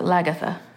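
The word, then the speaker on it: Lagertha: Lagatha? – Ragatha or Lagatha. They're both terrible names. Her name's Lagatha? – Lagartha. – Lagartha. – Yeah, but they pronounce it Lagatha.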